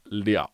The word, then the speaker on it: 了: He says, in the sentence 吃个面包饱不了, character le is pronounced liao.